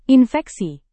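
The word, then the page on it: infeksi: indonesian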